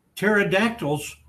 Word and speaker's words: pterodactyls: When my grandchildren come over, sometimes they complain about pterodactyls coming into our bathroom in the night. And they well, why can't you hear it, Grandpa? Well, the P is silent.